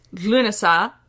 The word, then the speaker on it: Lughnasadh: Uh, so it's spelled L U G H N A S A D H. It is pronounced Lunasa, and it is spelled much closer to that in modern Irish.